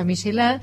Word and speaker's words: Michelin: Hay algo que es una marca de neumáticos que se escribe Michelin, pero que después son, en realidad tiene que ver con la gastronomía, se pronuncia Michelin,